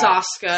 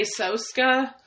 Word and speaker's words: Soska: Cause it's Saska. I used to say Soska. – Saska. – But it's Saska.